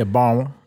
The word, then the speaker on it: Barnwell: Yeah, Barnwell. And, you don't say the N.